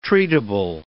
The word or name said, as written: treatable